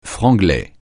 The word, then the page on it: franglais: francais